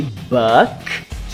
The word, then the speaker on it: buck: Ó, não vai confundir a pronúncia de buck que é o dólar, com book, que é o livro. Buck, book.